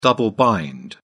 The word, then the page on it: double bind: British English